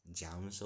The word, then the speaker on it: Jaymes: And I'm James Selvis. Actually, it's James, that's J-A-M-E-S, but for me, I prefer the J-A-Y-M-E-S. Some people pronounce it as James or whatever.